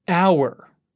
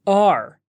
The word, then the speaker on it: our: Now, the next word is our. O-U-R.